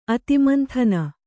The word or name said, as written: ಅತಿಮಂಥನ